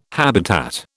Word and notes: habitat: British English

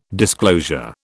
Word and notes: disclosure: British English

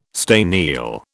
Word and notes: staniel: British English

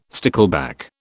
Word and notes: stickleback: British English

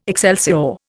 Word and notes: excelsior: Indian

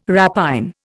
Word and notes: rapine: Indian

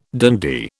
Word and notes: dandy: British English

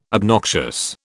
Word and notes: obnoxious: British English

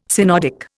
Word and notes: synodic: Indian

